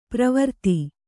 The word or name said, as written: ಪರಿವರ್ತ